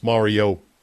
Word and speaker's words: Mariota: But now we know it's Mariota is the correct pronunciation.